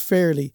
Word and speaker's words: Faerly: Um, so, Faerly, it is spelled F A E R L Y, but pronounced fairly. Just like, I'm fairly looking forward to opening this.